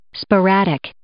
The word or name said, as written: sporadic